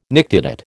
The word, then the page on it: nictitate: American English